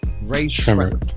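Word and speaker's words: Rae Sremmurd: How you pronounce it? – Ray Schremmer. – Schremmer.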